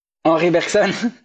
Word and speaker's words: Henri Bergson: Oh, sorry, I'm going to say Henry Bergson. Henri Bergson. You say the name again? – Henri Bergson. – There you go.